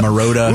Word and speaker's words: Marotta: How do people not know how to say Marotta? – Mariota, Marriott. – Marriott? – Marotta.